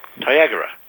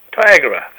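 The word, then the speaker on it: Tyagarah: And your new location, can you pronounce it for me? – Tyagara. – Tyagara, that'll do.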